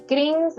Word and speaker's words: Krings: Y Kring es como Rings, pero con K al inicio, K-R-I-N-G-S.